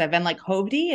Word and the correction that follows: Hovvdy: And then today I was like, oh my God, it's Howdy.